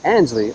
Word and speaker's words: Ansley: A-N-S-L-E-Y. – A-N-S. Ansley.